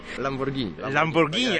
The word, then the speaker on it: Lamborghini: Lamborghini. Spaghetti, a nie spaghetti.